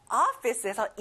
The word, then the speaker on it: office: 자, 오피스가 아니라 어 발음이에요. Office.